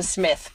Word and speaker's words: Psmith: I can't help but just leave it to Smith. P. Smith. – Yeah. – Like P-S-M-I-T-H. – Yeah, it's a great one. – The P is silent.